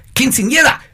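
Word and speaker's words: Quinceañera: And then on Saturday, we got to celebrate with the girls, Quinceanera.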